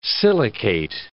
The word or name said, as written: silicate